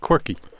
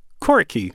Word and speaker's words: Coriky: It's a new trio from Washington, D.C., and they are called Quirky. Right? That's how you say it, right? – spelled C-O-R-I-K-Y.